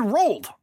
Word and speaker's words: Roald: No, I said Roald.